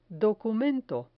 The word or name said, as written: documento